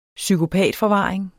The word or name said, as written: psykopatforvaring